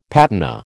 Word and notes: patina: American English

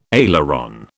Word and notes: aileron: British English

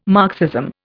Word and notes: Marxism: Indian

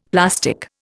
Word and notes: plastic: Indian

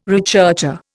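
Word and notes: recherché: Indian

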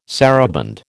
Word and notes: saraband: American English

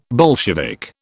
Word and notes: Bolshevik: British English

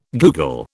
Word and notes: googol: British English

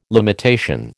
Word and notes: limitation: American English

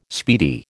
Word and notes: speedy: American English

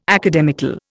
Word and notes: academical: Indian

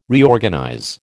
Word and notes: Reorganize: American English

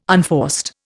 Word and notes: unforced: Indian

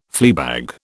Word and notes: fleabag: British English

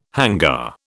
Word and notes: hangar: British English

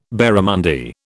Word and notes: barramundi: British English

